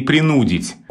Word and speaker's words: принудить: Я как-то запомнил для себя «пригубить» и «принудить». Вот два странных глагола, где очень хочется сказать с другим ударением, но ударение падает на «у», и вот мне было удобно их запомнить парой «пригубить» и «принудить».